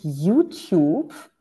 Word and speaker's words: YouTube: Ich würde sagen, YouTube. – Gewonnen. – YouTube ist richtig ausgesprochen, genau.